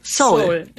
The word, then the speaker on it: Seoul: Es gibt im Koreanischen zwei O's. Das eine ist O und das andere eher so ein O. Und das Soul O ist das O. – Oh, Soul. – Soul.